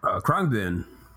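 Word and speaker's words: Khruangbin: You can find Caliphon, Cité, Destroyer, The Dirty Projectors, Juliana Barwick, Krungbin, am I pronouncing that name correctly? – Krungbin. Yeah, kind of.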